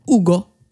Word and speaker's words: Hugo: Hugo i Spanien. Ja. Vet du hur det uttalas då? – Nej. Ugo. Ugo.